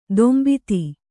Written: ತಂಬತ್ತಿ

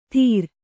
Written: ಧೀರ

